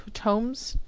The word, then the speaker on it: Tomes: Am I pronouncing that right? Tomes? Tomes of Terror? T-O-M-E-S? – Yes. – Oh, Tomes. So like Tomes.